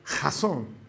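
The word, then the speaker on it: jazón (חזון): Esa palabra que tú ves ahí traducida como visión, en hebreo se pronuncia jazón que literalmente significa dirección de Dios.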